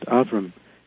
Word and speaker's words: Avram: And that's how she pronounced it, Avram. She's a Catholic woman from New England. She didn't say Abraham or anything like that.